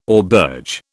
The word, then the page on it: auberge: British English